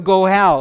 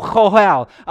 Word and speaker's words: goel: a goel, or chohel. That's the proper way to pronounce it.